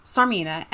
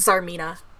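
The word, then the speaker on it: Tsarmina: Is it Sarmina or is it Zarmina? – Sarmina? Well, I was pronouncing it Sarmina. – I always have, but then I realized it's supposed to be, like, based on the Russian, like, pronunciation of Tsar. – Oh, yeah, so, like, Zarmina? – Yeah, I think it's Zarmina.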